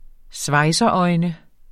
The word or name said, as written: svejseøjne